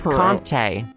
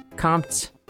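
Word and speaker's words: Comté: I think someone told me it's Compte. Compte. I think it is two syllables.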